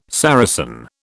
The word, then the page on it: Saracen: British English